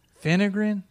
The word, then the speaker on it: fenugreek: Took a Vinogren. Fenugreek? Is that how you say it? – Sure.